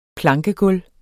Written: plankegulv

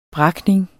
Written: brakning

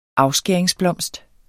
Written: afskæringsblomst

